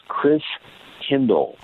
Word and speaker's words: Christkindl: And the German pronunciation of Christ child is Christkindl.